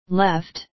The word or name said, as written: left